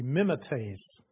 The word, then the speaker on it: mimetes: It is the word mimetes, or actually, if I put the accent, if I pronounce it properly, it would be mimetes, spelled M-I-M-E-T-E-S in English.